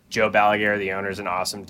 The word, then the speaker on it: Balaguer: Balaguer? – Yeah.